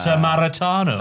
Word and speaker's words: Sammartino: Comparing MJF and Wardlow to Larry Zbysko and Bruno, in your words, Sammartino.